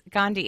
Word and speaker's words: Gandhi: Gandhi. I think that's how you pronounce it. I always pronounce his name wrong. Isn't that how you pronounce it? Do you know? – Yes, Gandhi.